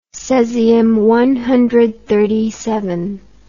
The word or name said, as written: cesium clock